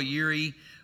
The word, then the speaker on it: Jireh: How many of you know if God is Jehovah Jireh, or depending on how you want to pronounce it, some people say Jehovah Yireh,